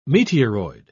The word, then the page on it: meteoroid: British English